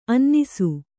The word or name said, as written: ಅನಾನಸ